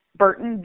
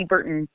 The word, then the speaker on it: Burton: Connie is K-O-N-N-I, Burton, B-U-R-T-O-N. I have a Facebook page, which is Connie Burton TX.